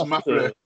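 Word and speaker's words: Ma Fleur: Yeah, it's Breathe by Cinematic Orchestra, and it's from the album, how am I going to pronounce this? Mafleur. – It is, it's Mafleur.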